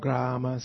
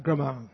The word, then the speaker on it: Grama: Grama Seva. It's not Grama, it's Grama. You have to pronounce the diacritics properly. It's not Grama, Grama. Everybody say Grama.